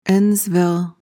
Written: endsville

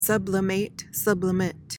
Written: sublimate

